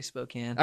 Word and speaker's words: Spokane: A lot of people think it's Spokane, but it's actually Spokane.